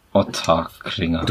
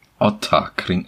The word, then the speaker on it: Ottakringer: Darauf, darauf, darauf ein Schlückchen Ottakringer. Du kannst es, wie, wie oft trinkst du es jetzt? Du kannst es immer noch nicht aussprechen. Otterkring. Ach.